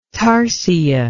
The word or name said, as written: tarsia